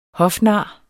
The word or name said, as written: hofnar